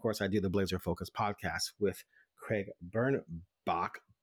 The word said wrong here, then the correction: Birnbach: but excuse me, Burnback it's, it's back, and I always say Bach because it's spelled like Bach.